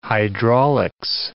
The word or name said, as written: hydraulics